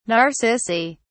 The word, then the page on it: narcissi: British English